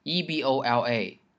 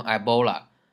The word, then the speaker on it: Ebola: 大家想埃博拉，再一看它的拼写 ,EBOLA,EBOLA, 我们就想啊、哦、是不是读成 Ebola? 啊错，它不是 Ebola, 它的英文名叫做 Ebola,Ebola。